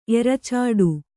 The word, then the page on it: ಎರಚಾಡು: eracāḍu